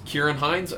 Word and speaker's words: Ciarán Hinds: Kieran Hines? – Kieran Hines? Okay, that's probably, yes, how you say it.